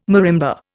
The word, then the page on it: marimba: Indian